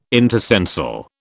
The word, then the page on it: intercensal: British English